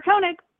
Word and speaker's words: Koenig: Koenig? Faydra Koenig.